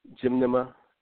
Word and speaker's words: Gymnema: It's Gymnema. Gymnema. And then the other part is Sylvester, S-Y-L-V-S-T. Sometimes they spell it T-E-R or T-R-E.